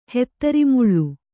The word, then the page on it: ಹಾತರುಮಾಲು: hāta rumālu